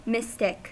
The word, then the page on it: mystic: American English